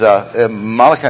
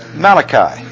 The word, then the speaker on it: Malachi: And as we've said previously, humorously, it is Malachi, not Malachi. It's not a book written by an Italian prophet named Malachi. This is Malachi, meaning my messenger. Behold, I'm going to send Malachi, if you're going to pronounce it per Hebrew or as Hebrew.